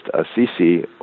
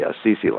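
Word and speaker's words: al-Sisi: And I'm not talking about just Assisi, or, by the way, that's how it's pronounced, because there would be an elision of the L in Al, so his name would be actually Assisi, like St. Francis of Assisi.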